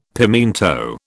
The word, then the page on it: pimiento: British English